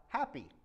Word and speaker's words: Hapi: And the third god is the god Happy. And that's spelled H-A-P-I.